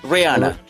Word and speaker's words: Rihanna: ¿Cómo ¿cuál, es la pronunciación? ¿Es Rihanna? – Rihanna. – En inglés.